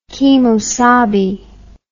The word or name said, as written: kemosabe